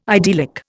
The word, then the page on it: idyllic: Indian